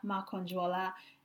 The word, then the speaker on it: Makanjuola: Facebook is at Abiola Makanjuola